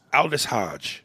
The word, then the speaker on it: Aldis: It's A-L-D-I-S. – A-L-D-I-S. Aldis? Aldis Hodge.